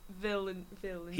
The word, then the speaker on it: Villeneuve: Denis Villeneuve.